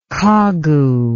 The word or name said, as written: kagu